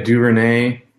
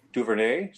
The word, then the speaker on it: DuVernay: Yeah, DuVernay. – DuVernay.